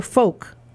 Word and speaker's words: folk: Y-O-K-E. The same is true for folk. F-O-L-K. You don't pronounce the L.